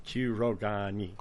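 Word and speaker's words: Kurogane: Kurogani. – Just say each syllable, each two letters separate, you'll be fine. – Kurogani.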